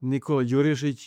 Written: Nikola Djurisic

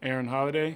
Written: Aaron Holiday

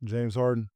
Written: James Harden

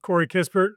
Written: Corey Kispert

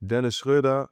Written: Dennis Schroder

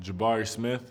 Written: Jabari Smith Jr

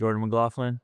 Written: Jordan McLaughlin

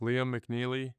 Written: Liam McNeeley

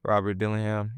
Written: Rob Dillingham

